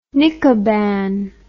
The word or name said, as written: Nikoban